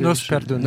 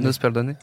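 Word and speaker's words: Que Dios nos perdone: Le film est signé Rodrigo Sorogoyen, à qui on devait déjà Que Dios no perdone. Est-ce que je le prononce bien? – Nos perdone. – Nos perdone. – Non, pas terrible.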